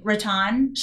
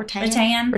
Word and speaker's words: rattan: So we have some rattan shades that we were really successful. – Is that how you say it? It's not rattan. – Rattan. – Rattan. – Rattan. Okay.